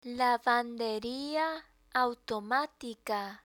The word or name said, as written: lavanderia autimatica